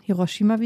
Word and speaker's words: Hiroshima: Hiroshima, wie ich gelernt mhm. habe, wie die Japanerinnen und Japaner sagen. Ähm, die Betonung ist eher immer auf den hinteren Silben.